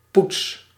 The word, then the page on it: polish: English